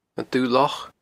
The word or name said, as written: Dubh Loch